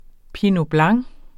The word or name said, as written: pinot blanc